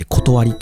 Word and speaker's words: 理: 世の中の理由の理って書いて断りって読むんですけど、